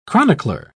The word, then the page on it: chronicler: British English